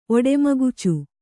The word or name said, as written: ಒಡೆಮಗುಚು